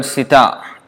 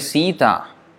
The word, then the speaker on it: sita: So that's why it is important to learn the correct pronunciation. It's not Sita, it's Sita. Sita means sugar candy.